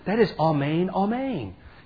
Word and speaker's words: Amen: That's how you pronounce it in Greek, Amen.